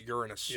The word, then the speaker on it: Uranus: It's like, well, it's pronounced, as Neil deGrasse Tyson said, once you're after the age of 12, it's Uranus.